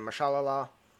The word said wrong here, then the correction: Mahershala: Mahershala. – Mahershala. Ali. Okay.